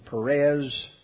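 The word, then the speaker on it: Peres: It's God's judgment, Mene, Tiki, and Perez is the pronunciation of that last one, which we just read over.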